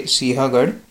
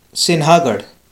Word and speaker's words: Sinhagad: So Rajiv Masand pronounced it as Sinhagad, which is very wrong. And also some YouTubers pronounced it as Sinhagad. So it is Sinhagad, basically. The spelling is like Sinhagad, but the N is silent in that.